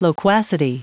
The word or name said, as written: loquacity